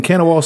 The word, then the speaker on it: Kanawha: Yeah, in Kanawha City. That's how you're supposed to pronounce it.